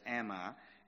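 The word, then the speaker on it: Ammi: Hosea said, say to your brethren, Ami, or some people pronounce that Ami, and to your sisters, Ruma.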